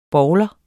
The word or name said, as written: BOWLER